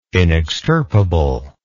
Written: inextirpable

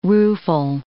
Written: rueful

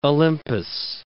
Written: Olympus

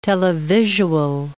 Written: televisual

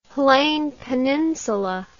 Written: Lleyn Peninsula

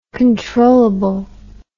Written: controllably

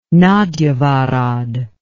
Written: nagyvarad

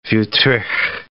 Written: Wuthrich

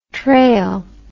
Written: treille

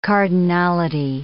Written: cardinality

